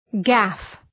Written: gaff

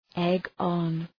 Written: egg-on

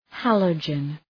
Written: halogen

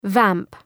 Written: vamp

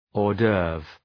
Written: hors d'oeuvre

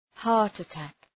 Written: heart attack

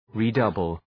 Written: redouble

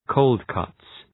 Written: cold cuts

